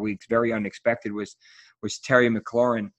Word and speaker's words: McLaurin: And it's not McLaren because it has a U in it, even though I love McLarens. So they want to call him F1, but that's not how you say it.